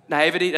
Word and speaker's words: naivety: Is it naivety? Is that how you say it? Naivety, did I say it right? I always look to, if I ever ask about how a word, I always look to Cody because he always knows. Naivety, all right.